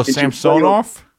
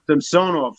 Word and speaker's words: Samsonov: Like, you know, like with Washington, Saranarov, sorry if I'm saying the name wrong, really, went with two rookie goalies. – Oh, Samsonov? – Samsonov.